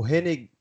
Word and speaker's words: Renegade: Aí tem o, o Renegade... Como que pronuncia? Renegade mesmo? – É, mano, exatamente. Renegade.